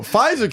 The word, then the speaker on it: Pfizer: Bro, Pfizer can have a silent P and an F, and y'all can't do fucking just P-E-T.